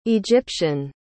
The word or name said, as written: egyptian